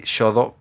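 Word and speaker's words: Xodo: Ah, se escribe X-O-D-O, -O, d o vale Así pone Shodo PDF.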